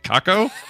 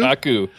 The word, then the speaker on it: Kaku: Kako? Kaku? – Kaku, yeah.